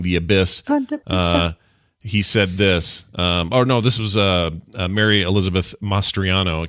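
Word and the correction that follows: Mastrantonio: I can't say her name. Master Antonio. – Master Antonio. There you go.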